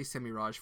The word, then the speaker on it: Semirhage: Semirhage is one of the I'll say Semirhage for those who prefer the soft G.